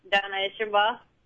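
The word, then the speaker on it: Donna Eshenbaugh: Donna Isherba.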